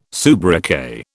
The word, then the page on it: soubriquet: British English